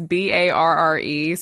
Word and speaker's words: barre: It's Barre. So it's like fake ballet. – Like bar.